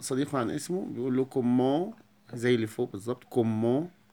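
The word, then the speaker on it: comment: عشان يسأل عن صحة زميله بيقول له كومون، كومون التي لا تنطق والان بتنطق من الانف.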